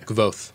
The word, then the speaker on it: Kvothe: Say his name, because I can never say it right. – Kvothe. – Kvothe. – It's how I say it. It's K-V-O-T-H-E, Kvothe.